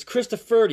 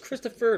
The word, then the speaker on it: Christopher: I've gone up to people, I think it actually happened in a county once, they like asking me how to pronounce my name. I'm like, it's Christopher. The R doesn't fit on the form.